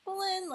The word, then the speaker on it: Belen: like Belen, like Helen. Mm-hmm. So eventually, you know, I adapted it to Belen, like Belinda.